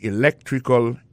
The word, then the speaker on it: electrical: I majored in electrical engineering, electrical engineering, ça veut dire accent tombé dans electrical là, tombé sur deuxième syllabe là,